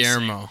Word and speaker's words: Guillermo: I don't know how to pronounce Guillermo.